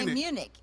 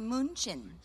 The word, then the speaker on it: Munich: In English, we say Munich. – There we go. – They say München.